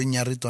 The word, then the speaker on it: Iñárritu: De González iñarito eh, Iñárritu. ¿Esa dónde la ve uno? – Netflix. Netflix. – Mm. Ah. – Ya llegó Iñárritu a Netflix.